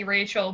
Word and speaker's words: Rachael: So on Instagram, I'm Maggie Rachel, but it's spelled R A C H A E L underscore, and then on Twitter, it's Maggie underscore Rachel.